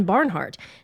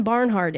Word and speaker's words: Barnhardt: Then if you can add, okay, so you like, say for example, Anne Barnhart, type Anne Barnhart in, spell it correctly, A-N-N, no E, and then B-A-R-N-H-A-R-D-T, you got to spell it right.